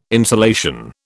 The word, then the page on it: insolation: British English